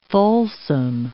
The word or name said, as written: Folsom